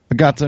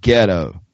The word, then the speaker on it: gato: It's gato. Here, we're going to do a lesson, a Spanish lesson. Ready? Gato. – Gato. – Good. Ready? Gato.